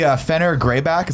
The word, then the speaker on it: Fenrir Greyback: And we see Fenrir Greyback.